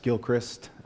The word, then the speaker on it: Gilchrist: My name is Josh Gilchrist. Jacob pronounced it correctly at first. Just think the first syllable of Christmas, Gilchrist, that is my name.